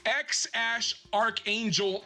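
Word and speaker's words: X Æ A-12: but the pronunciation of the name is X-Ash Archangel Musk.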